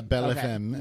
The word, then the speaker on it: Belle Femme: Am I pronouncing that right? Belle Femme. – Belle Femme. Yeah, that's... Belle Femme. – I'm going to spell it out like you did the one. Knights of Bali. – Oh, Belle Femme. That's I was going to say, you're the one with the culture, not me. Belle Femme. – Yeah, Belle Femme is how that's pronounced.